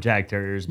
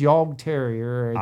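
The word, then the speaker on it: Jagdterrier: But it is Yorg Terrier. – I pronounce everything wrong. – Yeah, so. – It's if you everybody out there has had a drink once or twice and it has been around Jägermeister. So same word.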